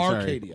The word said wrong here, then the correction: Acadia: Right, Acadia. – Acadia, no R, yeah.